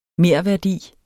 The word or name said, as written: merværdi